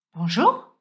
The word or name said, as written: Bonjour